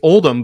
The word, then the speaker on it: Oldham: My last name's Oldham. I mean, it's Oldham, but it's spelled like Oldham.